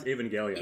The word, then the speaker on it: Evangelion: How do you pronounce Evangelion? – Evangelion.